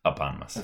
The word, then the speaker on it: eponymous: So Ryan, while Ryan's pouring these out, I was told I was pronouncing eponymous incorrectly last week. It's not a hippopotamus. It's eponymous.